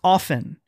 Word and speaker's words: often: But it's pronounced often. You ignore the T.